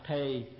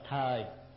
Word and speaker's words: thời: Rồi kế nữa, thời tình, ngoài Bắc thì đọc thì, trong Nam mà đọc là thời.